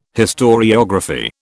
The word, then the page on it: historiography: British English